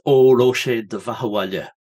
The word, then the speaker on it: Óró sé do bheatha abhaile: So it's Oroche Dehu Baha Valia. Close enough, Richard. It's Óró sé do bheatha abhaile by Sinead O'Connor.